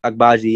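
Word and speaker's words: Agbaji: And then on to, I'm going to butcher this, Okai Egbuaje. – Yeah, that's how you say it. It's Egbuaje, yeah.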